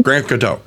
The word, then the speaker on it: Grand Coteau: Grand Coteau.